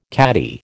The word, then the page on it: caddie: American English